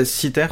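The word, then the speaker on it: Cythère: Alors Citer, qu'est-ce que c'est Citer, c'est une île grecque euh, qui, existe toujours sous ce nom, Citer, euh, et euh, en tout cas en français on le prononce comme ça,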